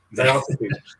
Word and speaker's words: Diotrephes: Diotrephes.